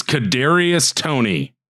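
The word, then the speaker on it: Kadarius Toney: I think I'm going to butcher this, honestly. Kadarius Tony? Kadarius Tony. It is Kadarius Tony.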